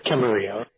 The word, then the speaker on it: Camarillo: Yeah, it's Camarillo. It's a Spanish name.